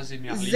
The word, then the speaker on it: Zemerli: Ça s'écrit comment ça, Zemerli? – Z-E-M-E-R-L-I.